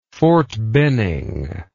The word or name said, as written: Fort Benning